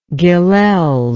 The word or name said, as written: Gilels